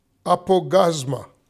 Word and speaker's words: apaugasma: It is the word apogasma. Apogasma. It's spelled A-P-A-U-G-A-S-M-A. Apogasma.